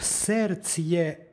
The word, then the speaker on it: srcje: psalmu u trećem stihu pročitali smo ibo v srcje bezakonije. V srcje u duhu srpskog jezika pročitali smo bez d.